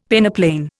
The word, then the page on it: peneplain: Indian